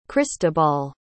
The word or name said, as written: cristobal